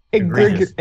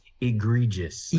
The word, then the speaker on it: egregious: We have Ermic the Evil versus Egbert the Edgerlurious. Egregious words. – I'm hearing a pirate's quote in my head. It's pronounced egregious.